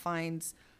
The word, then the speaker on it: Fiennes: Ralph Fiennes? Is that how you say it? – Fiennes, yeah.